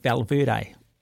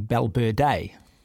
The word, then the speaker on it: Valverde: And so you get a guy like Valverde, but it's actually Balberde.